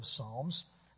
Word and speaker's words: psalm: The names that we have for the book of Psalms, there's more than one. Our English word psalm, P-S-A-L-M, comes from the Greek word, and they pronounce the P in Greek. They called them the psalmoi, and that's the plural Greek for songs.